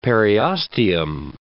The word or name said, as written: periosteum